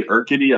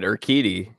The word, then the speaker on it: Urquidy: Framber Valdez, Luis Garcia, Christian Javier, Jose Urquidy. I don't think I'm saying that right. But all those guys. – No, that was actually pretty good. Urquidy. Yeah. – Urquidy.